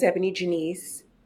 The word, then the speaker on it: EbonyJanice: Um, my name is Ebony Janice. That is spelled E B O N Y J A N I C E.